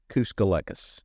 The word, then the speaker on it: Kouskolekas: Kouskolekas. – Kouskolekas. – Just like it's spelled.